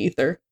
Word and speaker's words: Aether: It's called Into the Aether, A-E-T-H-E-R. Mm-hmm. You can find it on Twitter, at Into the Cast. – So you pronounce it Aether? – Yeah. – Okay. – Because we have a thing at work, and I call it the ether. I don't know if that's correct. – Both work.